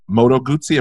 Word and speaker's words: Moto Guzzi: You've got Moto Guzzi.